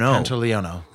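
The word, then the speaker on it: Pantoliano: Joe Pantaleone. – Pantaleone.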